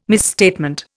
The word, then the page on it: misstatement: Indian